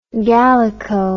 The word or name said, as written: Gallico